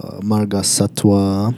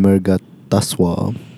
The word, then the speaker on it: Marga Satwa: Di samping Advance Marga Satwa. I really like calling them Marga Taswa. – Because they don't have vowels. – Because, you know, it's a deliberate mispronunciation.